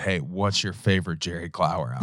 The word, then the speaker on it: Jerry Clower: We're talking about More Goodins by Jerry Clower, released in 1981.